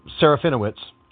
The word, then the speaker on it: Serafinowicz: Uh, that was swiftly followed by episode two, which featured uh, Peter Sefero, uh, ah, I can never say his name, Serafinowicz,